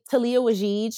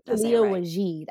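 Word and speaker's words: Taliah Waajeed: Talia Wajid. I think that's how you say her name. T-A-L-I-A-H and then W-A-J-I-D.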